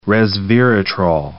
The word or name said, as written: resveratrol